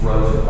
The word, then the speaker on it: Rosen: Being Rose and I just R-O-S-E-N is pretty simple, but it even makes me today that people can't pronounce Rose, but they pronounce it Ros and Rosen. And so I have to usually explain to them it's Rose and R-O-S-E-N.